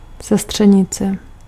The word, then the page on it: Cousine: /kuˈziːnə/